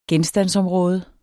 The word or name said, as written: genstandsområde